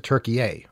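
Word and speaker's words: Türkiye: So you're supposed to pronounce it Turkey-A, or there's other ways of pronouncing it, but you're not supposed to say Turkey anymore.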